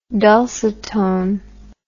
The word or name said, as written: dulcitone